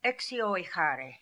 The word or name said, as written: Exioehary